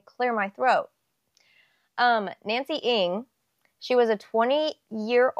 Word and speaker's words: Ng: Ng. Ng. It's it's spelt N G but it's pronounced like ing.